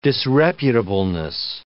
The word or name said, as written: disreputableness